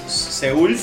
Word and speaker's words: Seúl: O sea, se inundó Seoul, pero más Gangnam, que es la parte baja. – Seoul es Seúl. Seúl. perdón, perdón, porque bajé la, la, la, pronunciación al argentino perdón, tenés razón.